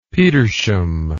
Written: petersham

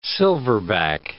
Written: silverback

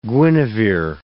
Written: Guinevere

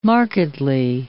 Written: markedly

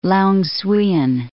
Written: Long Xuyen

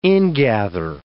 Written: ingather

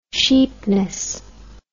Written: cheapness

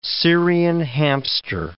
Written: Syrian hamster

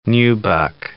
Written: nubuck

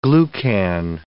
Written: glucan

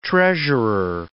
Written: treasurer

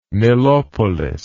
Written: Nilopolis

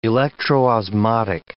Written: electroosmotic

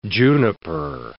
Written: juniper

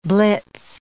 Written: blitz

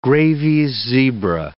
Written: Grevy's zebra

